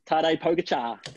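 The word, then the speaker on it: Pogačar: And I'm not even going to try and pronounce his name here, Pogacar. – Tadej Pogacar. – Pogacar.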